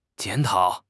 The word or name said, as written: 检讨